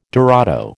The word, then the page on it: dorado: American English